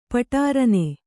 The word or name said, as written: ಪಟೀರನೆ